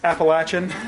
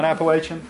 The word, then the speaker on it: Appalachian: So I went to get my master's degree at Appalachian State University, and yes, it is pronounced Appalachian, not Appalachian.